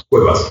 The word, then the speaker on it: cuevas: And I would suppose, have to go to the cambio or the cuevos even. Did I pronounce that right? Cuevos? – Cuevas. – Cuevas. – It's like cave. – Right, okay. – Yeah, it's cave. Like a cave because it's dark.